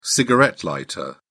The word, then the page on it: cigarette lighter: British English